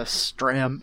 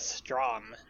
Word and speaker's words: Stram: this is the only time that the Stram and the Mac are ever mentioned. – oh, I, I, I, was about, I, was about. to pronounce it as Strom.